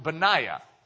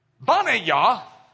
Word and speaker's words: Benaiah: But Yah, when you do Bana Yah, that is a name. It's actually a proper name in the Hebrew of one of David's mighty men. Banaya. You probably would pronounce it Benaiah.